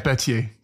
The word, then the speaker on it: Berthier: His chief of staff, Baron Berthier, is that how you say his name? – Mm-hmm. Berthier.